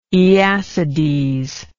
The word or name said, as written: aeacides